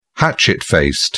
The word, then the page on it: hatchet-faced: British English